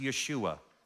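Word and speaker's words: Yeshua (ישוע): Just so you know, Jesus is the Greek form of the Hebrew word or name Joshua, which is pronounced Yeshua.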